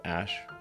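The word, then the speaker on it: Æ: and then the AE is like pronounced ash、 yeah and then、 A12 is my contribution oh why A12? – Archangel 12, the precursor to the SR-71.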